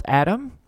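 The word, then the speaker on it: Atom: It's spelled Adam. Pronounced like that.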